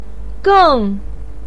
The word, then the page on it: geng: 4